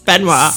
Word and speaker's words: Benoit: So today we are going to be covering the case of Chris Benoit. – Yes, Benoit.